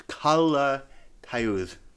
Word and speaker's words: Calatayud: Um, Calatayud is how you say it.